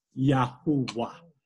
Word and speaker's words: Yahuwah: It is Yahuwah. That is how we are to pronounce the name of God.